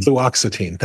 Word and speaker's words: Fluoxetine: I have difficulty with that as well. Fluoxetine. – Fluoxetine. Thank you.